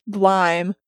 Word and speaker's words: Gleim: It turns out that the name GLEIM is pronounced Glime.